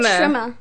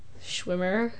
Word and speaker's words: Schwimmer: How do you say his last name? – David Schwimmer. – Schwimmer. – Schwimmer. – Oh my god, Schwimmer.